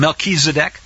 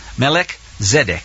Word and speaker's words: Melchizedek: He was a tzaddik, melech, tzaddik. That's where this a little bit twisted pronunciation comes from. Melchizedek, Melchizedek. Back to the words of Hosea.